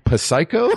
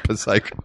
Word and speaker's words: Psycho: And it's called, I hope I'm pronouncing this correct, Psycho? There's a P that up top that I'm not sure how, I've never actually said it out loud. – Oh, God. – Psycho.